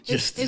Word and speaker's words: Just Us: It's no, you're saying it wrong. Justice. It's spelled like Just Us.